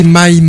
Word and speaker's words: Mai: On écoutait My, je vais le prononcer comme ça, ça s'écrit M-A-I, tout simplement.